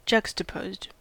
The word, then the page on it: juxtaposed: American English